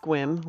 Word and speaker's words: Sequim: So yesterday, we biked into Squim. Spelled Sequim, pronounced Squim, we learned.